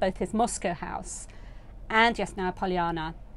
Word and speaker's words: Yasnaya Polyana: But um, so I went to Yasnaya Polyana, which I always pronounce incorrectly. How, how should I say it? Yasnaya Polyana. – Very there, good.